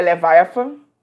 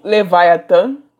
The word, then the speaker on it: Leviathan: O nome do, do álbum é Leviathan, ou Leviathan, como vocês queiram pronunciar. Eu pronunciei em inglês primeiro por questão de costume.